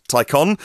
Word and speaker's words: Taycan: It's spelt Taycan, but pronounced Taycan, in case you hear it said differently or otherwise.